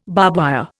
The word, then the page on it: barbwire: Indian